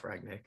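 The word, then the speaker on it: Rangnick: Is it Ragnick? – Yeah, Ralph Ragnick.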